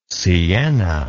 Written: siena

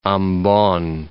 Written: Ambon

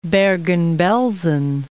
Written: Bergen-Belsen

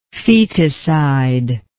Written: foeticide

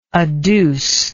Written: adducer

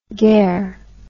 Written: ger

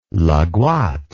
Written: Laghouat

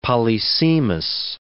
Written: polysemous

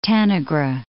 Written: Tanagra

